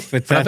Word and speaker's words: frappe: Frappe, that's how you pronounce it. Not a frappe.